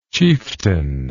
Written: chieftainess

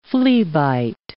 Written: fleabite

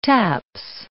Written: taps

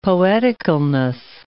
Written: poeticalness

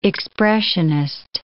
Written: expressionist

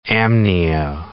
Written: amnio

